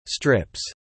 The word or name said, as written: strips